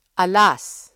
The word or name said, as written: alas